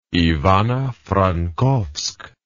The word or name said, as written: Ivano-Frankovsk